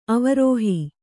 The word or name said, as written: ಅವರೋಹಿ